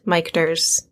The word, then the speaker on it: Michter's: except it is not Michter's. It rhymes with Dichter's.